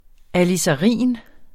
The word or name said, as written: alizarin